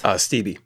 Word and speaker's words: Steeby: The record was recorded at Sound Lounge Studios in Orlando, produced by Greg from Cashed Out, Greg Shields, and Mike Steeb. Is that how you pronounce? – Steeby, yeah.